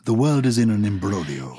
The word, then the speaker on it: imbroglio: And is that how you pronounce it? It's spelled imbroglio, isn't it? – It is. – But is that not correct? – It's a complicated situation. Imbroglio is Italian. Imbroglio. – Imbroglio. – Yes. – I-M-B-R-O-G-L-I-O.